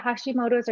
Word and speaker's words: Hashimoto: Is it Hashimoto? Am I saying that right? – Yeah, Hashimoto's or Graves' disease, hyperthyroidism or hypothyroidism.